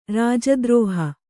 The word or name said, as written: ರಾಜದ್ರೋಹ